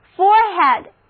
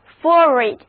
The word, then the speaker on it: forehead: forehead，大家注意这个词有两个读音，一个是 forehead，一个是 forehead，两个读音都对。